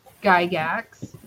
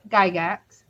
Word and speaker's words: Gygax: Psycho Goreman is from the planet Gygax. Did I say that right? – Yep. – Okay. From this planet Gygax.